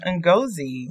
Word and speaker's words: Ngozi: You said it right the first time, Ngozi.